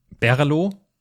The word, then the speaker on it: BRLO: Schreibweise ist B-R-L-O. Das führt dazu, dass alle Leute sich fragen, hey, wie spricht man das denn aus? Ich habe jetzt gelernt, es heißt Berlo.